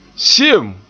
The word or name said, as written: se'em